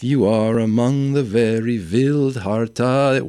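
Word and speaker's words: Vildhjarta: It's pronounced Vildharta, as I learned, not Vildjarta.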